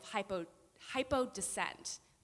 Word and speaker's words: hypodescent: But what it really is this thing, this fancy word that I learned today called, it's an example of hypodescent. That's the first time I've said that out loud, and I think that's how you say it, yeah.